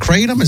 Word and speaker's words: kratom: Is it Kratom? Is that how you pronounce that? – Yeah.